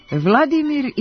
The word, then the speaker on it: Vladimir: Vladimir ili Volodimir? Hoćemo li se prikloniti ruskom ili ukrajinskom izgovoru imena koje smo u vreme postojanja Sovjetskog saveza redovno prenosili kao Vladimir?